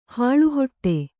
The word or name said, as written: ಹಾಳುಹೊಟ್ಟೆ